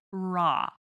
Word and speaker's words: raw: Uh, so anyway, this is real and raw no L. Pronunciation. – Yes. No L on the end of raw.